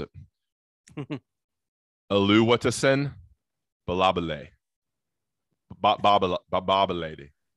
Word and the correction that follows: Babalade: AKA Big Tree. Oluwatosin. Oluwatosin Babalade. Babalade. That's easy. Last name is Babalade.